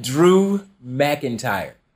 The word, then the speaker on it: McIntyre: Drew McGeary? Who? Yeah. – McIntyre? I don't even know how to say it. – Drew McIntyre.